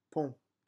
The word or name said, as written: pont